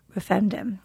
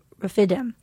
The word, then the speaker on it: Rephidim: This is when the Amalekites fought Israelites at Rephidim. I mispronounced it. I apologize. I think it's Rephidim.